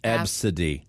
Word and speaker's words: Abcde: The name is spelled out A-B-C-D-E, but it's pronounced Absidy.